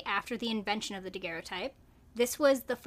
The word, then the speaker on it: daguerreotype: Okay, so we did confirm the pronunciation of... – Daguerreotype. – Yeah, there we go. – Daguerreotype.